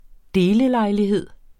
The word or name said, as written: delelejlighed